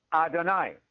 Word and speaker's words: Adonai: El Shaddai rhymes with Adonai.